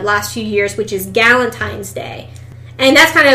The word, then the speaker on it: Galentine's Day: And what, you call it Galent, like G-A-L? – Right. So Valentine's Day spelled with a G.